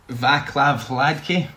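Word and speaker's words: Václav Hladký: I can't believe you came to me first for the pronunciation here, but Vaclav Hladke. Am I getting that? – Yeah, I've got him as well.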